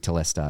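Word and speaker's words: tetelestai: Yeah he says T telestai or T telestai. I don't know how you say it. Yes. Uh, but T telestai is how I say it.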